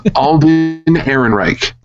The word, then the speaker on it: Alden Ehrenreich: Alden Ehrenreich is being cast as young Han Solo.